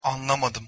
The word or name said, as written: anlamadim